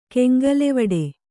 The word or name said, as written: ಕೆಂಗಲೆವಡೆ